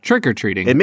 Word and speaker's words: trick-or-treating: I put the emphasis on treat. You put the emphasis on trick. – Yeah, trick-or-treating.